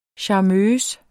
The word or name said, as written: charmeuse